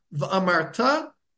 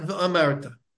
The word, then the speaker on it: v'amarta: When the word means about the future, v'amarta, you shall say, it should be said, they need to be said milara, means you need to put the stress on the last syllable, v'amarta, and not v'amarta.